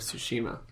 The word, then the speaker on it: Tsushima: We have more updates on Ghost of Tsushima.